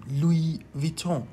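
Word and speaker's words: Louis Vuitton: لوی ویتون بر اساس آواها و فونتیک نزلیزه یا تو دماغی که در زبان فرانسه هستش لوی ویتون تلفظ میشه.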